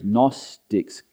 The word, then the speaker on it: Gnostics: They were known as Gnostics, silent G.